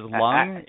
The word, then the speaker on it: Lung: I mean, my last name is Lung, and you'd be amazed how many people butcher it. I mean, it's like L-U-N-G. It's what you breathe with.